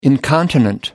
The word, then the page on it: incontinent: American English